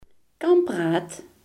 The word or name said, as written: tandwiel